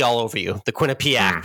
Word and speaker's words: Quinnipiac: It's pronounced Quinnipiac. Just the way it's spelled.